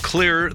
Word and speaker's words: Xlear: And today, with the CEO of Clear, that is spelled X-L-E-A-R, Nathan Jones.